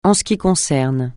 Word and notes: en ce qui concerne: francais

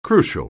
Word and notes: crucial: anglais

